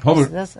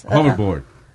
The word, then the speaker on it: hoverboard: estaba en un Hoover. ¿Tú sabes eso? No se dice Hoover. – Hover, hoverboard. – Uh, hoverboard.